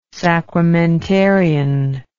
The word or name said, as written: sacramentarian